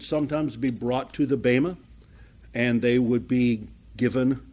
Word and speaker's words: Bema: This is where we come to the reward bench, or it's called in the in the scriptures called the Bema, B-E-M-A, long E. B-E-M-A, Bema.